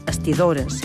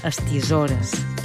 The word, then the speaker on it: estisores: Popularment, aquesta paraula es pronuncia amb D, estidores, però la pronúncia formal és amb S sonora, estisores.